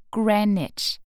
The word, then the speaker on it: Greenwich: Discovering Greenwich Village. Se prononce Greenwich.